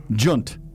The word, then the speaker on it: djent: it's spelled DJENT. – Oh yeah, gent. – Junt. – Junt. – Junt. Junt. And the reason it's called junt is because the guitars go junt, junt, junt, junt, junt, junt, junt, junt.